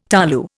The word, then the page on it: tolu: Indian